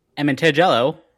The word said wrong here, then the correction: Amatangelo: I'm saying that right. – Amatangelo? – Amatangelo. – Amatangelo? There's a million ways you could say it.